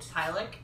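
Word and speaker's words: Twi'lek: It's got, like, Sabine, who's this Mandalorian warrior, and Hera, who's this incredible, I never say it right, Taliqui? – Don't. – Tylik.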